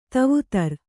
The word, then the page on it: ದೇವದಾರು: Kannada